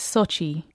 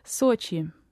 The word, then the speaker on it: Sochi: The anglicized pronunciation is Sochi. – Sochi. – Yes. – So how do the Russians say it? – Yeah, Sochi. – Sochi. – Sochi. Mm. The vowel is somewhere in between the British English O and O, so that's why it tends to be anglicized as Sochi.